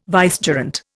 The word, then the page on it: vicegerent: Indian